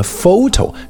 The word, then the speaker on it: photo: 大家想想为什么 photo 相片那个单词字母组合 PH 读成 F 就明白了。